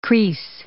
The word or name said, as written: kris